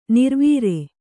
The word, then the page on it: ನೆರೆವರಿ: nerevari